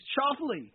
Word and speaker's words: Schauffele: Open had media leafing through the player's guide trying to learn how to pronounce his name. It's Schauffele.